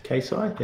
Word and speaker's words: Keisei: Keisei, yeah.